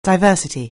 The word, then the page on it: diversity: anglais